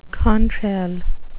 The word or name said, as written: contrail